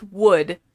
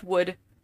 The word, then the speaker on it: Woude: It's just Wood. – It doesn't say that when I looked it up. – It's Wood, but it's spelled differently. W-O-U-D-E. That's Wood. Teresa Vanderwood.